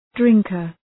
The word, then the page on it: drinker: ‘drıŋkər